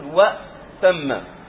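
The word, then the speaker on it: وائتم: هذا ليس فيها إشكال لأن همزة الوصل لا تنطق فتقول و، هذه الهمزة ساكنة فمباشرة تنتقل من الواو إلى هذه تقول وتم.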